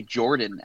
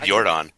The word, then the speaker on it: Yordan: I've heard people say Jordan Alvarez, like a soft J. – Oh, really? No. Yeah. I've always said Yordan.